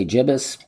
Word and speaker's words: gibbous: The moon is in its waning gibbous phase this week. Um, I like to say gibbous. Some may say gibbous. I like a hard G. Good, gibbous.